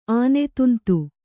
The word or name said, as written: ಆನೆತುಂತು